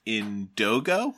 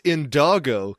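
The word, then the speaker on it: Indoggo: I believe it's pronounced Indogo. – I think it's Indago because it's Snoop Dogg's gin. – Okay. I thought it was Indogo, but I guess that would be 1G.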